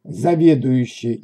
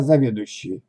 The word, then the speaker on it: заведующий: Заведующий, неправильно заведующий. И снова коварная буква «ю» при произношении теряется, но мы находим ее тем же легким способом, что и выше. «Я заведую» значит заведующий.